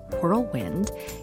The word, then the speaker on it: whirlwind: If you happen to detect special emphasis on the WH in whirlwind, you are bang on.